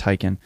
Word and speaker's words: Taycan: Okay, there's like the Taycan, or I think it's supposed to be pronounced Taycan, which is weird because they have the Macan. Like, don't you think it should have been the T- Tycon, yeah. – The semantics. – I don't know, but I think they say it's supposed to be pronounced Tycon.